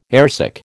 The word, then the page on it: airsick: American English